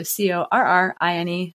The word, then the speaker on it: Corrine: My name is spelled funny. I've got two R's and one N in Corrine. So Corrine and then Malcolm, Malcolm.